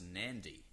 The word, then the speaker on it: Nadi: There's a place called Nadi, Which is pronounced Nandi. And if you pronounce it correctly, Nandi, you'll get a little wink from the Fijians.